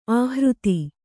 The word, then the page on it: ಆಹೃತಿ: āhřti